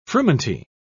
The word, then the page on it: frumenty: British English